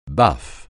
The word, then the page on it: baffe: francais